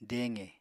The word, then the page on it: deqe: apatani